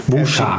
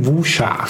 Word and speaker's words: Wuxia: Vúsá. Vúsá. Ez a helyes kiejtése ezeknek a ugye kardozós, igen, igen, igen. kardozós harcművészes filmeknek.